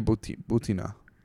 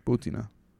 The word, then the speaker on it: Бутина: It's Maria Batina. I hope I'm pronouncing it correctly. Maria, welcome to the show. – Привет, здрасте. Меня зовут Мария Бутина. – Мария Бутина. Бутина. Yeah.